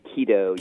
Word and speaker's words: keto: Am I saying it right? Keto or keto? I always fuck this up. It's actually keto. Keto. Yeah. Ketogenic.